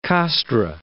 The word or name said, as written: Castres